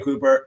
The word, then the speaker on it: Cooper: I think his name was Quade Cooper. I think it rhymes with Hooper.